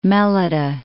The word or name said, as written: Melita